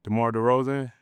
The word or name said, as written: DeMar DeRozan